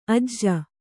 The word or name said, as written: ಅಜೆ